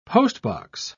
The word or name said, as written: postbox